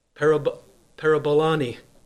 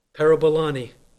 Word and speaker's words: parabolani: The word there in verse 30, risking, that word risking is the Greek word pronounced parabolani, parabolani.